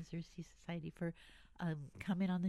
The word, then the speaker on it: Xerces: It's X E R C E S. Uh, let's see, Xerces is how it's pronounced.